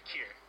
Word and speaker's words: Kir: Or yeah, Kier. K-I-R, Kier.